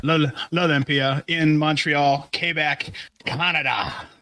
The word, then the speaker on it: L'Olympia: And yeah, the venue is L'Olympia. – Oh, that's how you say it.